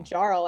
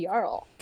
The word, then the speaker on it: Jarl: A video game called Skyrim that people like I've to play in their mother's basements, and there's a character in it called a Jarl. Jarl is a common noun. – Oh. – And after that game came out, suddenly all men could pronounce my name. – Love that. – I was no longer Kayte Jarl. I became Kayte Jarl.